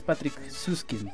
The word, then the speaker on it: Patrick Süskind: Sí, exacto, y son 2 montajes diferentes, por supuesto, porque me atreveré a pronunciarlo, es Patrick Süskind, ¿no? – Exacto. – Exactamente.